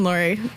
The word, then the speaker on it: Laurie: John Laurier Drive instead of John Laurie. And then the Calf Rope Bridge instead of the Calf funny. – Robe Bridge. – John Laurie.